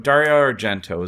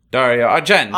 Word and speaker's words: Dario Argento: Yeah, there's like a weird, it's long at an hour and 40 minutes, It's usual Argento, Dario Argento is the director and co-writer. – No, you didn't say it right. – Dario Argento.